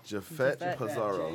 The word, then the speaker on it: Jafet Pizarro: Jafet Pizarro. – J-A-F-E-T yeah.